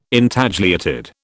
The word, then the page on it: Intagliated: British English